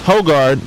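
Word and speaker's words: Højgaard: Is it Hogard? Is that how you say his name? – Correct. – Hogard, minus 4.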